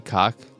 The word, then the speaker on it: Koch: And aren't there other pronunciations of K-O-C-H? You may be right. And I believe Coke Media is Coke Media. However, the game says its pronunciation of K-O-C-H, and it is indeed cock. And sometimes it's cock, but it's either a version of cock or caulk.